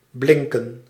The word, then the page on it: briller: /bʁi.je/; France